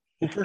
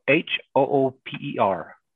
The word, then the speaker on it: Hooper: Hopper, Utah. Hopper. Hopper. Hooper. Spelled H H O O P E R. I say Hooper.